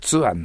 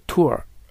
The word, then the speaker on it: tour: しかも発音がツアーになってる。そうじゃなくて Tour。